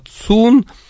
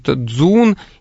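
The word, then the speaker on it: «цун» (琮): В частности, 2 основных типа предметов – это так называемые диски, которые по-китайски произносятся «би», и такие типа цилиндров, такие предметы, которые называются «цун». Значит, в некоторых местах их называют «дзун», и в некоторых «чхун».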